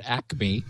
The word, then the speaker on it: ACME: There's a protocol that's been developed called ACME, and I guess we would pronounce it ACME.